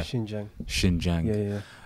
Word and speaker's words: Xinjiang: Xinjiang.